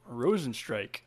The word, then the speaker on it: Rozenstruik: Rozenstruik.